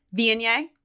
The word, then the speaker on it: Viognier: Viognier.